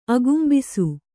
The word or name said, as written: ಅಗುಂಬಿಸು